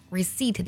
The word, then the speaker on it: receipt: Receipt 这个单词拼作 R-E-C-E-I-P-T，这里呢需要特别注意啊， Receipt 当中的字母 P 是不发音的。